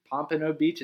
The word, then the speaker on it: Pompano Beach: Pompano Beach, is that how you pronounce it? – Yeah, Fort Lauderdale, yeah.